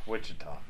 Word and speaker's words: Wichita: It's not pronounced Wichita. It's it's pronounced. Is it Wichita? Wichita. That's what it is.